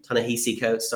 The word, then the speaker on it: Ta-Nehisi Coates: Ta-Nehisi Coates, sorry.